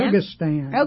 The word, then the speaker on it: Kyrgyzstan: Kyrgyzstan? How do you say that? – Kyrgyzstan.